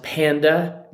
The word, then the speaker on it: Panda: My name is Sonu Panda. More often than not, it is pronounced Panda, just like the bear,